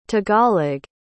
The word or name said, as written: tagalog